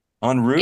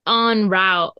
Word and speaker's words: en route: En route? – Yeah, en route, probably. You said on route.